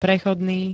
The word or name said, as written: prechodný